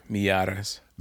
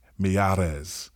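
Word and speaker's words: Miyares: Did I say that right? – Mieres. – Let me make sure I say that right. Mieres.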